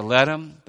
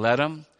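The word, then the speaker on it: Lethem: We went to Ledham. The British do not pronounce the TH as we do. It is that the H is silent in Ledham.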